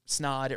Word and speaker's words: Snaadt: People are like, "Oh, how do you pronounce it?" And it's like it's snod. It rhymes with odd, so it just kind of like sticks in people's mind.